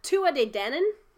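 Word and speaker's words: Tuatha Dé Danann: because Gaelic has a lot of letters that you don't say. It's the Tuatha De Danann.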